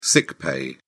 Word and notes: sick pay: British English

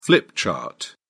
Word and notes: flip chart: British English

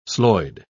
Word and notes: slojd: British English

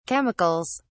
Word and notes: chemicals: British English

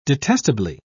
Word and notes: detestably: British English

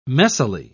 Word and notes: messily: British English

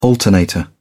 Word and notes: alternator: British English

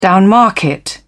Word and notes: downmarket: British English

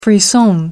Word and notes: frisson: American English

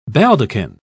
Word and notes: baldachin: British English

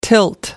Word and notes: tilt: American English

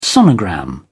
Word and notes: sonogram: British English

